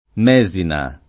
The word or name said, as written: Mesina